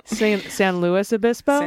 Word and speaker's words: San Luis Obispo: I really love that this person, and listen, thank you for all the corrections, but instead of saying like St. Louis, they said rhymes with poopiss. – Yes. San Luis Obispo? – San Luis Obispo.